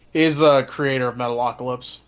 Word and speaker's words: Metalocalypse: Metalocalypse, am I saying that right? – Yes, Metalocalypse.